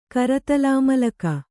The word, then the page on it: ಕರತಳಾಮಳಕ: karataḷāmaḷaka